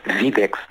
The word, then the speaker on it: Widex: Explica-me, antes de mais, eu quero pronunciar bem, Widex ou Widex? – É Widex, na verdade. Widex. É uma empresa Widex dinamarquesa, e lê-se Widex.